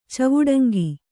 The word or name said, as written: ಚವುಡಂಗಿ